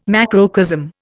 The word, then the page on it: macrocosm: Indian